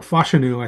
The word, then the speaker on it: Fashanu: So Fashanu definitely was, or Fashanu, I think we need to pronounce it because that's how he likes it.